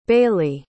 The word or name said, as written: bayleigh